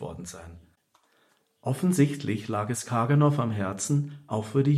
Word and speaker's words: Karganoff: Genari Karganoff, manchmal auch Korganoff ausgesprochen, war ein russischer Komponist armenischer Abstammung, der leider sehr jung im Alter von 32 Jahren starb.